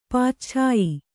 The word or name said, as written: ಪಾಚ್ಛಾಯಿ